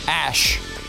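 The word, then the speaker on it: Æ: And the AE is pronounced Ash.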